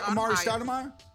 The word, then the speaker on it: Amar'e Stoudemire: Am I saying that right? Started Maya. – Who? Oh, Amari yeah, Stoudemire. – Amar'e Stoudemire. Fifty.